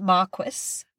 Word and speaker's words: Marquis: Anyway, I can attest that it is pronounced Marquis because I listened to the audiobook.